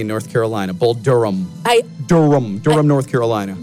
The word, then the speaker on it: Durham: By the way, I've wanted to call this movie Bull Durham the whole time just because of the way it's spelled. It's spelled with ham, actually.